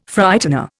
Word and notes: Frightener: Indian

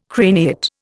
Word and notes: craniate: Indian

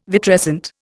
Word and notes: vitrescent: Indian

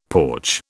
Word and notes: porch: British English

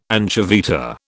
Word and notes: anchoveta: British English

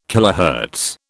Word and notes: kilohertz: British English

